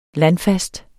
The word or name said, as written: landfast